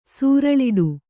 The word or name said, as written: ಸೂರಳಿಡು